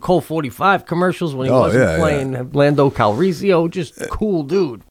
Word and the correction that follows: Calrissian: Calrissio is Italian. It's pronounced Lando Calrissian.